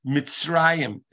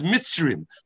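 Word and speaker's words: Mitzrayim (מצרים): Mitzrayim is pronounced like there's a vowel aleph in there. Mitzrayim. But it's written as Mitzrim with a chirik.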